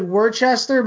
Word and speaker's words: Worcester: So I was supposed to perform in June up in Worcester, kid. – It should be pronounced like Worcester, but you guys are idiots up there.